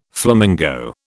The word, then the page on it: flamingo: British English